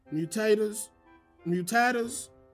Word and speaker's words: mutatis mutandis: I-S space M-U-T-A-N-D-I-S. – Mutatis mutandis. Wait, no, I said that wrong. – That almost sounded right, though. I almost believed it. – Mutatis mutandis? – Correct.